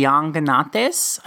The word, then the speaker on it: Llanganates: We're focusing on the region known as the Llanganates, I believe is how you pronounce that, because it's the double L, and I don't speak Spanish, but I'm doing my best.